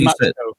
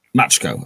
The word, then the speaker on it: Machiko: I say Matchco. – I say Matchco. – Matchco. Okay, the Americanization of Matchco,